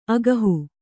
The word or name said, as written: ಆಗುಹ